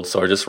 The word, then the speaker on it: Sorgius: And my guest today, Kim Sorgius, Sorgius, I've been told Sorgius rhymes with gorgeous, so I have to get the last name right, has come on board to talk about her parenting blog.